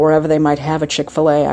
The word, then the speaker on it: Chick-fil-A: Another thing going on in the South, there's this restaurant called Chick-fil-A. And it's spelled C-H-I-K-F-I-L-A. But it's Chick-fil-A.